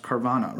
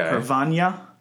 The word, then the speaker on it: Carvanha: So you might expect this to be pronounced pronounced Carvana, right? – Yeah. – That's probably how most people pronounce it. But the NH, when I look at it, is very Portuguese sounding. And in Portuguese, it has the equivalent of an Enye sound from Spanish. So okay. Carvana.